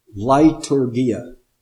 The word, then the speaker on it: leiturgia: And the word is, let's see, English pronunciation is liturgia. Liturgia. Now, I'll spell it with English letters so you can write it down. It would be spelled L-E-I-T-U-R-G-I-A, liturgia.